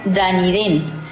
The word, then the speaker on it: Dunedin: La ciudad de Dunedin se pronuncia Dunedin, significa castillo sobre la roca, que es el nombre galeico de Edimburgo.